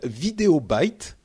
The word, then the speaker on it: Videobyte: Euh, c'est une app qui s'appelle Videobyte à prononcer avec l'accent. Avec l'accent anglais, tout à fait.